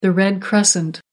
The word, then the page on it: red crescent: American English